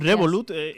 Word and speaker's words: Revolut: Profesor, seguimos, encontramos en El Economista que, no sé si lo digo bien, Revolut logra casi el 20% de las nuevas altas de clientes de banca. Adelante, ¿tú cómo lo dirías? ¿Cómo lo pronuncias? – Revolut.